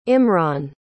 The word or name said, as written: imran